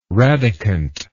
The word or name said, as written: radicant